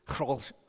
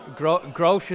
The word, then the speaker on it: Grolsch: Grolsch. Yeah, Grolsch is actually not the correct pronunciation. Grolsch is actually the English word. It's a Dutch beer. Is Grolsch. There you go.